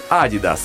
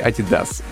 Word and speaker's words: Adidas: И поэтому наименование этой спортивной марки произносится как Adidas, а не Adidas.